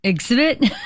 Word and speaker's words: exhibition: Exhibition. Clive, the A is silent. – That's right. – Excuse me, the H is silent. There is no A.